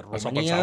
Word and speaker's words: Romania: Exacte, ho pronunciem bé, exacte, ho pronunciem bé, perquè com que ja sabeu que les nostres tòniques quan tornen àtones se pronuncien ús, és a dir, no hi ha cap pega. Romania, Hongria.